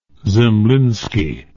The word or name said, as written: zemlinsky